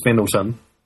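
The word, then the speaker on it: Fendalton: I'm in the rich people's area of Findleton. Actually pronounced as Fendleton, but anyway.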